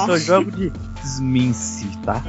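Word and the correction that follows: Tzimisce: Zimice. Esse aí tem a pronúncia no livro, terceira edição realizada. É Zimice. – Zimice? – Esquece o T. Esquece o T, Zimice.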